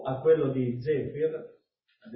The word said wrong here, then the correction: Ziph: deserto di Ziph, scusate, di prima Samuele 23:15.